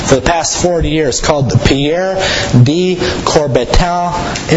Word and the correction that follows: Coubertin: International Fair Play Trophy. Hope no one speaks French here, because I really butchered that up. But the Pierre de Coubertin International Fair Play Trophy, the first trophy was ever given to Eugenio Monti for his fair play and sportsmanship there that day.